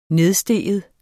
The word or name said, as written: nedstige